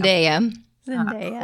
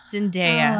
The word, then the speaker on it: Zendaya: And then I think Zendaya. Did I say her name right? – Zendaya. – Crap. Zendaya. Oh.